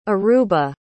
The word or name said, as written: Aruba